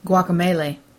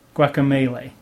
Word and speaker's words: Guacamelee: Not been playing very much, but I did play a new game called, I cannot say this properly, Guacamelee. Is that right? – Guacamelee. – Guacamelee. – Like guacamole. – Because it's guacamole, with melee, which is...